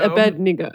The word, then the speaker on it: Abednego: It's Abednego.